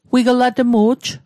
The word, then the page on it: wiglatmu'j: Mi'gmaq